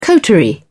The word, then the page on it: coterie: British English